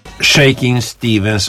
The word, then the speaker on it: Shakin' Stevens: Shaking Stevens och Tired of Towing the Line, som det korrekt uttalas.